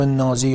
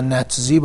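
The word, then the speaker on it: Nazi: حزب نازی یا نتزی با تلفظ اونها یعنی ناسیونال سوسیالیست.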